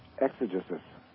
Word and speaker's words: exegesis: So would you like to hear exegesis? How do you pronounce that? – Exeges? – E-X-E-G-E-S-I-S. Exegesis. Okay, got it. Exegesis.